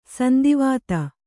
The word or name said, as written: ಸಂದಿವಾತ